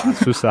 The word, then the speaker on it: Asus: 咁今个礼拜原来可能呢个概念一出，或者应该未呢个概念出，啲人都开始研发緊㗎。咁我哋有两个新聞啦，一个就系 ASUS Assist 系咪叫 Asus 啊？ – 系，官方读音 Asus。